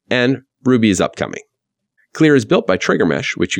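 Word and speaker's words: KLR: You can now move them directly to the cross-platform Knative framework using CLEAR, spelled with a K, that's K-L-R, standing for the Knative Lambda Runtime.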